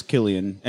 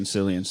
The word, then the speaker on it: Cillian: His name is Killian. It starts with a C, but it's a hard C. – Okay. – And we kept saying Cillian, so...